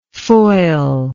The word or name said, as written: foilable